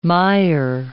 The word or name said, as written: mire